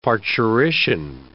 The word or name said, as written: parturition